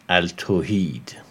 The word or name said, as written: Al-Tauhid